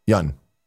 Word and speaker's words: Yeun: It's not Steven Yeun? – Yeun. – Oh, we got the Oh, okay. Well, that's just because I can't fucking pronounce it. – We would have said Ewan, though.